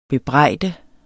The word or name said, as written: bebrejde